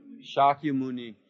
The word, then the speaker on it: Shakya Muni: Shakya Muni. Hear where the accent falls? Shakya Muni. Right? First syllable because it's long.